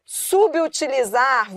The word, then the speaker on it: subutilizar: Inclusive, a pronúncia, segundo os puristas da língua, a pronúncia correta deve ser subutilizar. Porque você concorda que esse sub aí perdeu o hífen? E o B junto com o U forma aí uma nova sílaba, subutilizar.